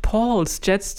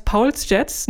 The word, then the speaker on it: Pauls Jets: Ich bin ja immer äh, versucht, Paul's Jets zu sagen, aber da es sich um eine Band aus Österreich, genauer gesagt aus Wien handelt, ist wohl Paul die richtigere Aussprache.